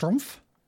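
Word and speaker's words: Schtroumpf: I think that's just Strumpf. – Yeah. – Okay. The Strumpfs. – I think they're just called the Strumpfs. – Strumpfs. That is one of the most, if you were going to spell that word with as many superfluous letters as you could, I feel like this has all of them. – Yeah. – You do not pronounce every letter in that word.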